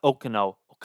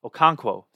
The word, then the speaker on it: Okonkwo: I really like looking at some of the second-year guys like Bellinger, Chigankwu. Oh, like that? Said his name right for the first time. Not Okuno. Okonkwo.